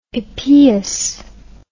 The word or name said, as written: epeus